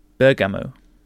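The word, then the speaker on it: Bergama: It still retains its ancient name, which in the mouth of a Turk is pronounced Bergamo.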